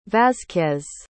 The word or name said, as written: vasquez